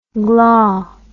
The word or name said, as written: glah